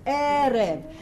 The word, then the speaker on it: Erev: Erev. Okay, remember the accent is on the first syllable, all right? This is Erev.